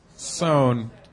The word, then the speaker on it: Soane: Speaking of London tourist things, we went to the John Soames Museum. Someone pronounce it for us. – Soane.